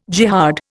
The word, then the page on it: jihad: Indian